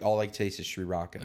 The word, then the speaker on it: sriracha: You know, and don't even think about freaking sending me Sri Raka to here. Okay, just listen up, Listerland. I want an Ishiraka. – I also want emails because I think it's pronounced sriracha.